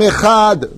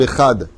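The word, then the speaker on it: ehad (אחד): La majeure partie, partout où je vais, dans tous les pays, Hachem echad. Mamash lo, lo Katouv ehad. Tu as changé le, le het en chaf. Pourquoi Il faut prononcer tel que c'est marqué.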